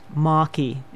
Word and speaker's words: Mackie: And this was, there's a little, my maiden name was Mackie, but that would be pronounced Maki the way it is. So I'm explaining that because I do use those two words in here, and they're different pronunciation, but they're written exactly the same.